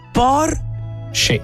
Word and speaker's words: Porsche: Il nome corretto è quello della Porsche e si pronuncia in due sillabe. Por. – Sce. – Sce. – Sce. – Sce. Quella E finale non è muta.